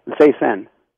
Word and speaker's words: Zaysan: Zaysan.